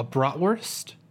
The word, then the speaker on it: bratwurst: A bratwurst. Am I saying that right? – Okay, a brat? Yeah, yeah. No, you got it.